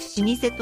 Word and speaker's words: 老舗: 試験あって、あ、ちゃんとだから漢字の読みとかも、うん、すっごい覚えてるのが、うん、老舗と書いて老舗と読む。